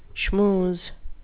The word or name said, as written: schmooze